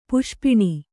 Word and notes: ಪಾಶಪಾಣಿ: pāśa pāṇi

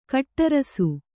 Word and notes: ಕಟ್ಟುರಸ: kaṭṭurasa

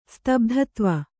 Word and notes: ಸ್ತಬ್ಧತ್ವ: stabdhatva

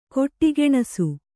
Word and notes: ಖೋಡಿಗಾಣಿಸು: Kannada

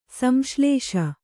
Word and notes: ಸಂಶ್ಲೇಷ: samsḷēṣa

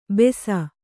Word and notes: ಬೆಸ: Kannada

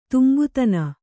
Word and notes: ತುಂಬುತನ: kannada

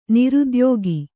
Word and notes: ನಿರುದ್ಯೋಗಿ: kannada

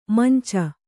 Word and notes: ಮಂಚ: Kannada

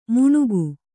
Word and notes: ಮುಣುಗು: muṇugu

